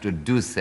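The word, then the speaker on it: Duse: Well, of course, it should be pronounced Deuce, yeah.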